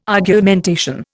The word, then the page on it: Argumentation: Indian